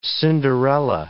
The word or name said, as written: Cinderella